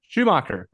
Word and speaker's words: Schumacher: Yeah, so this is The Lost Boys, directed by Joel Shoemaker. Is that how you pronounce his last name? – Wait. – He did Batman and Robin. Did I talk about this yet? – Yeah, Schumacher. – Schumacher, that's it.